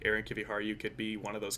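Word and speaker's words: Aron Kiviharju: I really like Aaron Kiviharju, if I'm saying it right. – Yeah, it's pretty close. You gotta just roll the R a little bit.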